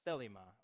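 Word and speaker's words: thelema (θέλημα): The word will in Greek is pronounced thelema, right, and conveys more a heart's desire is what thelema means.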